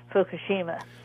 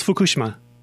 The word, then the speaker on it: Fukushima: Hey, thank you for the update on the nuclear plant in Japan, but you need to learn how to pronounce Fukushima. – No, it's Fukushima. Arigato domo. – No, the emphasis. – It's Fukushima.